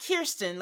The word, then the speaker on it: Kirsten: It's like Kerr, like fur. So it's Kirsten. Not Kirsten, like we've been saying.